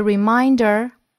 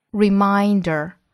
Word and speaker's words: reminder: 连读省读。Tomb Sweeping Day provides people with a reminder to visit and care for the graves of family and friends. 我们再来看美音。同样呢，清明节 Tomb Sweeping Day，要注意 tomb， tomb，末尾的 b 不发音，sweeping， sweeping，注意紧音的 e。Tomb Sweeping Day provides people with a 辅音和元音的连读 the。With a reminder, reminder, reminder, 注意第二个音节的发音 mine, mine, reminder, to visit and care for the graves of family and friends.